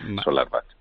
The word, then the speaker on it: SolarWatt: Los alemanes ponen la W como si fuera una B. SolarWatt.